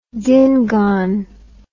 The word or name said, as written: Dingaan